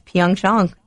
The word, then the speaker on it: Pyeongchang: It's Pyeongchang. That's the pronunciation.